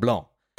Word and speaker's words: Blanc: And he came back and said, okay, well, I didn't want to say this, Matt, but you've got to stop pronouncing the C on Laurent Blanc's surname, Laurent Blanc. And he was right.